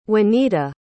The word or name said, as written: wanita